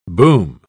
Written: boom

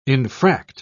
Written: infract

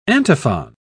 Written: antiphon

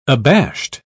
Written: abashed

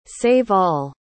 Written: save-all

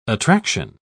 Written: attraction

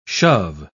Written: shove

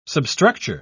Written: substructure

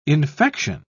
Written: infection

